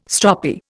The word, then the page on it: stroppy: Indian